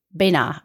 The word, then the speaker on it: Beynat: And Chateau Benat, I think is how you're going to pronounce it. B-E-Y-N-A-T.